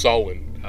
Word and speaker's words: Samhain: That whole season, it's actually pronounced Samhain.